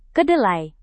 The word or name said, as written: kedelai